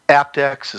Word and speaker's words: aptX: And the other thing is that aptX is starting to finally, you know, and for our listeners that don't know what aptX is, it's spelled APTX.